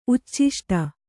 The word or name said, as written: ಉಚ್ಚಿಷ್ಟ